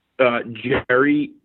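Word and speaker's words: Gerry: Jerry, say it like it's spelled with a J, but it's with a G.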